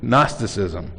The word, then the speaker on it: Gnosticism: It's a funny word, starts with a G, but the G is silent, Gnosticism.